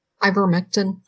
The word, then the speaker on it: Ivermectin: Ivermectin.